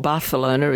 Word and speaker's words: Barcelona: We're going to Barcelona, or Barcelona as it is correctly pronounced,